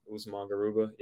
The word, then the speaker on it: Garuba: I would and then they also signed Garuba, is that how I pronounce his last name, to a two-way? – Usman Garuba, yep.